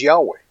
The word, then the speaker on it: Yahweh: that name was written in the Hebrew as four letters, Yod, He, Vav, He, pronounced as Yahweh in English, although we can't be sure of the exact dialect ancient pronunciation.